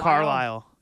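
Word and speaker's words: Carlile: Carlisle, yeah.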